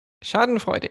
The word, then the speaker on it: Schadenfreude: Schadenfreude. – Schadenfreude.